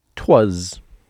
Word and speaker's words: 'Twas: Definitely not a normal English word, twas. But it actually goes back to something I talk about all the time. I am obsessed with vowel reduction. When a vowel sound like A gets kind of squished and becomes That's a schwa. Well, sometimes we go even further from vowel reduction to vowel deletion.